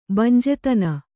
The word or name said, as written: ಬಂಜೆತನ